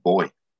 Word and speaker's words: buoy: Like, used to say buoy. So, you know, the buoys that are in the water and bouncing up and down. And Americans say buoy. We just used to say boy.